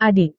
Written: adik

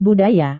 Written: budaya

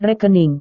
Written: rekening

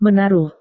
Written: menaruh

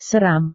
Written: seram

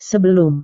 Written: sebelum

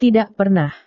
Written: tidak pernah

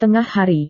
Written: tengah hari

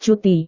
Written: cuti

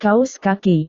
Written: kaus kaki

